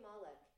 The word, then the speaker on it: Malek: Rami Malek.